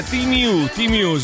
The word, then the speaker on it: Temu: Temu è Timiu, si pronuncia così.